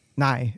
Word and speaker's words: Nye: It's pronounced Nye, so it's Bill Nye.